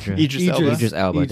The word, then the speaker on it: Idris Elba: How do you say that guy's name? Idris Elba. There you go.